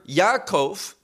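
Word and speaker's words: Yaakov (יעקב): The term Yaakov, that's how it's pronounced in Hebrew,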